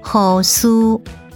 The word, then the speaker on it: 护士: 护士台语念作护苏，护苏。